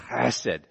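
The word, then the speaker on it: Chesed (חסד): It's hesed, which I didn't pronounce because in Hebrew you'd have to go hesed or something like that. Like you're going to spit. Chesed.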